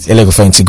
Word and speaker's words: Elekofehinti: Elekofeyinti.